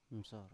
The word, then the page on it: msor: Biak